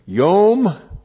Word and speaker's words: yom: Because, and then we have the noun day, yom. Y, long, O, M, yom.